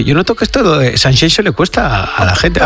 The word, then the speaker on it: Sanxenxo: y te cuento que este fin de semana en Sanxenxo, no Sanxenxo, como dicen. – Qué bien lo pronuncias, Sanxenxo, es como Shakira.